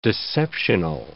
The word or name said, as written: deceptional